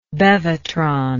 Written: bevatron

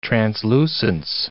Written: translucence

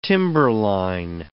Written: timberline

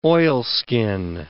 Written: oilskin

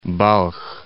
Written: Balkh

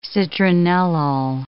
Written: citronellol

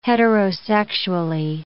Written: heterosexually

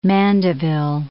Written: Mandeville

